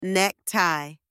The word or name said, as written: neck tie